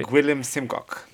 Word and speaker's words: Gwilym Simcock: Guillem Simcock.